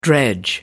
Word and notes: dredge: American English

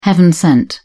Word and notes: heaven-sent: British English

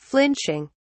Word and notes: flinching: British English

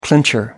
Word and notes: clincher: American English

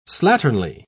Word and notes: slatternly: British English

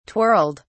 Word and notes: twirled: British English

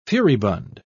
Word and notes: furibund: British English

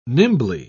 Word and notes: nimbly: British English

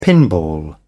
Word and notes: pinball: British English

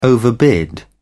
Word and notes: overbid: British English

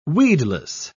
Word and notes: weedless: British English

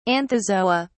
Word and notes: anthozoa: British English

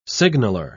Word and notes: signaler: British English

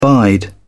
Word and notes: bide: British English

